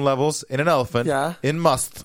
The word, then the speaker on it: musth: bull elephants go into must. Must. – What? How do you spell that? – M-U-S-T-H. You can also say must. If you must, you can pronounce it must.